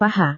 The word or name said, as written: paha